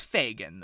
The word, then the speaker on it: Fegan: Is it Fegan or Fagan? – I would like to say Fagan, but you're right. It's spelled Fegan, not with two E's, but it's F-E-G-A-N. – Yeah, Fegan Floop. – It's Fagan, though, because the kids.